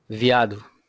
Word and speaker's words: viado: Viado. – Viado? – Yes, viado. – Hmm. How do you spell that? – V-I-A-D-O.